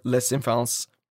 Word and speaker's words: les enfants: And this is Au revoir, les enfants.